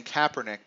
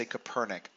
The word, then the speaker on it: Kaepernick: Recently I started saying Kaepernick, but I used to say Copernic. I think just because Copernicus, part of my brain wants to do that.